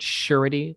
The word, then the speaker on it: Sureti: Yeah, it's a company called Sureti. It's spelled S-U-R-E-T-I.